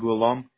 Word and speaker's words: Ghulam: My first name is Ghulam. It's G-H-U-L-A-M.